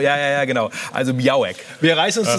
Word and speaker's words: Białek: Und Bartosz Biau äh, genau, Białek wird da, glaube ich, ausgesprochen.